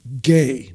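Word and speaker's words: gay (Γῆ): The Greek word for land is gay, not G-A-Y, that's how you say it, but it has nothing to do with the homosexuals, but it's gay.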